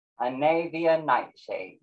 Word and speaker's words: Anavia Nightshade: I was saying Anavia Nightshade. – Anavia Nightshade, yes.